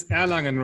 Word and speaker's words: Erlangen: He's the professor of medicine at the University of Erlangen. Did I say that right? – It's pretty close. It's Erlangen, Roxana.